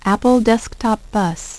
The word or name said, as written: Apple Desktop Bus